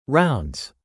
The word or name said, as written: rounds